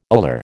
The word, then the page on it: ular: American English